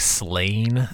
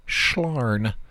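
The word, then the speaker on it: Sláine: By the way, in case you're wondering, if you ever see that comic Slain, it's a 2000 AD. It's actually pronounced Schlarn.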